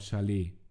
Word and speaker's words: chalet: It's called chalet. The T is silent.